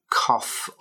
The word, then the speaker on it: cough: It's spelled C-O-U-G-H, but the sounding at the end is like a f, cough, like two Fs, double F, cough up, like the word rough that has the same sound.